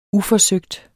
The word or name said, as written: uforsøgt